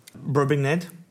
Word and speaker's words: Brobdingnag: Brobdingnag.